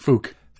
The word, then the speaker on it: phoque: They spell it P-H-O-Q-U-E. – Yeah, it's French way to spell it. – Fuck off. Fouk? Is it Fouk or Fock? – Fouk.